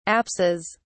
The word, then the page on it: apses: British English